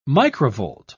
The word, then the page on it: microvolt: British English